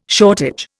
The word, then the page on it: shortage: Indian